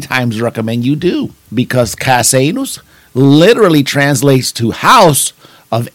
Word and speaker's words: Casanus: It is called Casanus, and that's spelled C-A-S-A-N-U-S. You heard it right, Casanus.